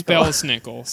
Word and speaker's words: Belsnickel: Belsnickel. – Belsnickel. – It's Belsnickel.